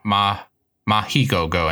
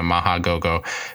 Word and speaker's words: Mach Go Go Go: They say like Ma Mahi Go Go instead of Maha Go Go.